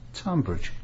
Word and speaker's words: Tonbridge: Do you say Tonbridge with it T-O-N? – No, it's Tunbridge, isn't it? – But then there's no difference yet, you know.